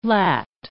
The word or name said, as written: lat